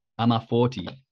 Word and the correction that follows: Amor Fati: I always say this wrong, but Ama Forti is the Latin, Amor Fati, sorry,